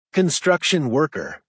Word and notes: construction worker: American English

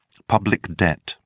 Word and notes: public debt: British English

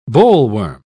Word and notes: bollworm: British English

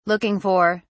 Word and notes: looking for: British English

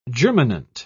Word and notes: germinant: British English

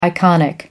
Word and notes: iconic: American English

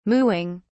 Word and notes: mooing: British English

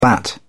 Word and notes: bat: British English

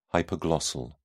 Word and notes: hypoglossal: British English

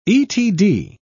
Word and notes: etd: British English

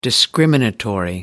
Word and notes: discriminatory: British English

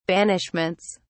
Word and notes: banishments: British English

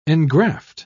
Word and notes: engraft: British English

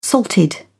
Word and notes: salted: British English

